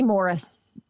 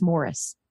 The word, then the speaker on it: Maurice: The three brothers, Barry, Robin, and Maurice, were best friends and all had houses right next to each other. – Might be Morris. I think it's spelled Maurice, but it's Morris.